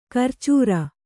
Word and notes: ಕರ್ಚೂರ: karcūra

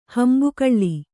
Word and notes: ಹಿಂಬಗಲು: Kannada

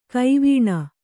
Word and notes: ಗವಣಿ: kannada

